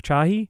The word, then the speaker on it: Chahi: It's a new studio founded by, am I pronouncing this correctly, Eric Chahi? – I believe that's accurate.